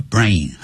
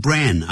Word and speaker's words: brain: Brain 就是脑袋的意思，叫 brain。很多人会念成 brain 啊，这个念法是不对的，叫 brain。